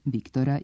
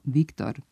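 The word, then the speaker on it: Victor: Tada i mnogo godina kasnije nije bilo obavezno naglašavanje francuskih imena na posljednjem slogu, što se donekle zadržalo do danas, tako da ime čuvenog francuskog pisca uvijek izgovaramo Viktor, a ne izvorno Viktor.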